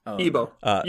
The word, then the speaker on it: Igbo: Igbo. – Igbo. You don't pronounce the G.